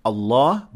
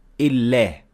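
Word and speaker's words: الله: So in three cases, we will not say Allah, but we will say Illah. The first, if the letter before Allah has kasra, we say it like that.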